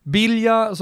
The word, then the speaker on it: Biglia: Bilja, som man skulle säga, om man bara uttalar det på italienska, GL blir ju EI. Så då blir det bilja.